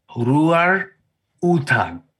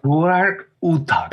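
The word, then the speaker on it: Roar Uthaug: And apparently the way you really say his name is it's Roar Utog. Ooh. Is how you pronounce it. – Say cool. Like you say it so cool. – Roar Utog.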